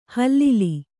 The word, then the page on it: ಹಿಲ್ಲೋಲ: hillōla